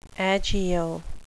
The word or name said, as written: agio